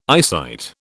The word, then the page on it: eyesight: British English